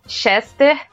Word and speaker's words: Chester: Mas que é o Chester, Chester igual se escreve mesmo a comida, Chester Whitmore.